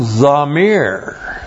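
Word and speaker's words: zamir (זמיר): There's another noun, zamir. Z-A-M long I-R, zamir.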